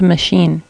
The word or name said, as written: machine